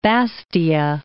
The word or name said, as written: Bastia